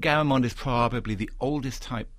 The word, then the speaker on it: Garamond: Garamond? – Yeah, absolutely. Garamond, yeah.